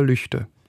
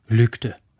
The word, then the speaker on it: Lügde: Und wenn man davon ausgeht, dass ein deutscher Ortsname so ausgesprochen wird, wie die gewöhnlichen Ausspracheregeln anzuwenden sind, dann würde man erstmal sagen, der Ort heißt Lügde. – Ja. – Weil das G nach einem Vokal als ein G oder ein K gesprochen wird.